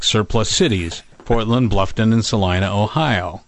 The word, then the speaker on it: Salina: And, of course, we had to help them out to say it's Salina, not Selena.